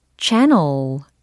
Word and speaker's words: channel: channel channel channel channel 해보세요. channel 어우, 채널 아니고 channel, channel 이렇게 해주시면 되겠습니다.